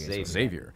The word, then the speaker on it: Xavier: Although, kudos to pronouncing it right. – Yeah. – We normally always get Xavier. – Xavier.